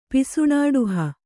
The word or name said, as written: ಪಿಸುಣಾಡುಹ